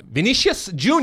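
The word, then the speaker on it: Vinícius Jr.: Number 9, Vinicius Jr. Yeah.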